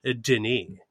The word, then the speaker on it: Denis: It might be pronounced Dennis, but there's only the one N, so I'm inclined to lead with Denis, like Denis Villeneuve, who I also appreciate.